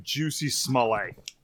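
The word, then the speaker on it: Jussie Smollett: The dude pronounces his name Juicy Smollett.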